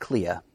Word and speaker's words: Clea: And would you say Clea or Clea? How do you pronounce it? – I've always said Clea.